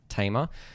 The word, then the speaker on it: Thema: It's spelled Thema. I don't really know how to pronounce it, but from some of the videos I've seen, it's pronounced Tamer.